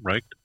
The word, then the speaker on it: Reich: How do you say the last name? – Reich. – Whatever. He is. – You know, like the Nazis.